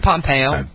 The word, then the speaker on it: Pompeo: I was immediately drawn in to the character that the Ellen Pompeo, is it Pompeo or Pompeo? How did you pronounce her last name? – I think Pompeo. – Pompeo.